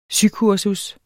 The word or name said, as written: sykursus